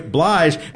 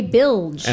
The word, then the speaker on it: Blige: Blige. What did Leland say? – Mary J. Bilge.